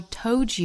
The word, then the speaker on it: told: I do the same thing I told you that I never would. 我们注意 told, 单词内呢有一个舌侧音。在发音的时候呢是 all, 舌尖要去抵上齿音。